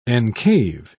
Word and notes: encave: British English

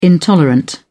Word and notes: intolerant: British English

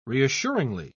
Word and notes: reassuringly: British English

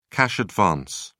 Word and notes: cash advance: British English

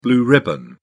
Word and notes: blue ribbon: British English

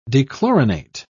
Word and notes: dechlorinate: British English

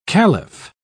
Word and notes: calif: British English

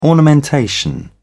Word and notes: ornamentation: British English

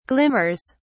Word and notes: glimmers: British English

